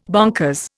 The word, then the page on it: bonkers: Indian